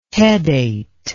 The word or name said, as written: pedate